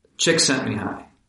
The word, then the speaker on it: Csikszentmihalyi: This is an idea most famously associated with Csikszentmihalyi is one pronunciation of that formidable last name. – Csikszentmihalyi.